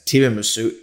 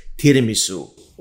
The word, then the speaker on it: tiramisu: Everybody else keeps pronouncing it as tiramisu. It's pronounced tiramisu.